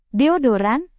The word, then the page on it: deodoran: Indonesian